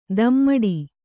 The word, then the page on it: ದಮ್ಮಡಿ: Kannada